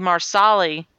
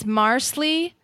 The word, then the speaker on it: Marsali: Yeah, I think if she'd wanted it to be Marsali, yeah, she would have put S-A-H. – The point is, it's three syllables. If it's Marsley, that's two syllables. Marsley. Well, and then the A is kind of skipped.